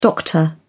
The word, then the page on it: doctor: British English